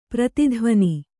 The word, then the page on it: ಪ್ರತಿಧ್ವಾನ: prati dhvāna